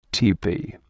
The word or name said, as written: tepee